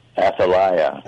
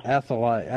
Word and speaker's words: Athalia: And I will tell you, I did had a chance to review it here in the Bible, I tend to pronounce it Athalia, but you pronounce it Athalia? How do you say it? – Athalia. – Athalia.